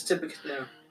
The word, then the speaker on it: Tippecanoe: I don't know how to say this. Tippecanoe. I think it's Tippecanoe. Tippecanoe curse, right? – Yes.